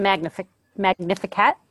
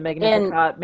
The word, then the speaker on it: Magnificat: The Magnificat? Is that how you say it? – Yeah, but I mean, that's not commonly used. Yeah, the Magnificat, Mary's Magnificat out of Luke.